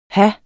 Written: have

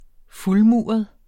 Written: fuldmuret

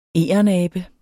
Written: egernabe

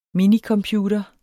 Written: minicomputer

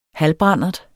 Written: halvbrandert

